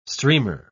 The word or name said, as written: streamer